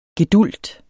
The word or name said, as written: gedulgt